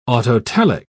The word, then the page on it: autotelic: British English